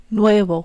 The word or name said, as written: nuevo